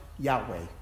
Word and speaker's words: Yahweh: Biblical scholars surmise that the letters would have sounded out Yahweh.